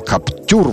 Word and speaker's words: Captur: А 27-28 числа в Сочи Renault Captur, российский Captur Его нужно и произносить на российский манер. Каптюр, вот так вот о нем говорить.